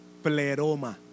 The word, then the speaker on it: pleroma: Por eso es que somos el y así mismo se pronuncia para los que están escribiendo. Pleroma de Dios.